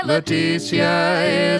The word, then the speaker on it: Letizia: L-E-T-I-Z-I-A. – Do I pronounce that fifth way? – Leticia is the Queen's first name.